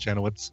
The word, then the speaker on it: Janowicz: Anyway, it's just pronounced Janowitz.